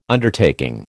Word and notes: Undertaking: American English

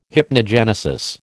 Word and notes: hypnogenesis: American English

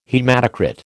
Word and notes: haematocrit: American English

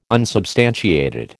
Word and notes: unsubstantiated: American English

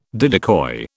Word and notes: didicoi: British English

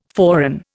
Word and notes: forerun: Indian

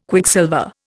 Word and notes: Quicksilver: Indian

